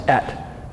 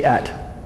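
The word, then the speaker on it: Et (את): The Hebrew is, that Hebrew word spelled Aleph and Tav is pronounced Et. Everybody say Et.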